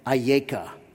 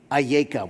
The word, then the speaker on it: Ayeka: In Hebrew, you may remember you read from right to left, and this is pronounced something like Ayeka. One word, Ayeka.